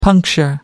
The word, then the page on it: puncture: American English